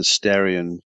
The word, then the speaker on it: Starion: I stopped worrying about names of cars a long time ago when you had the Starion, which was actually a mispronunciation, but became the actual name of the car, including the badge. With the R, not the L.